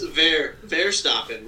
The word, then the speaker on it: Verstappen: Max for Stopping. Thanks for Stopping. Max Verstappen with a V.